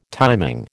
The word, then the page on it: timing: American English